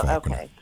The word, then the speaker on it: Falconer: And my last name is Falconer. Mm-hmm. It's like Falconer, depending on. – You said F-A-L? – C-O-N-E-R. It's pronounced Falconer. – Okay. – And the Falcons are winning. I'll say it's Falconer.